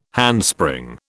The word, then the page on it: handspring: British English